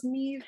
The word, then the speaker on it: Neve: Neve. I know it's normally pronounced Neve, but I feel I have to remind myself that it's Neve because in my brain I say Nev.